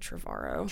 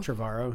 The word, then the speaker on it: Trevorrow: How do you say it? – I've always said Trevorrow. – Trevorrow, yeah.